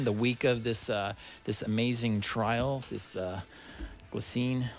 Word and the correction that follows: Ghislaine: I keep on saying her name wrong. It's Ghislaine. Ghislaine Maxwell.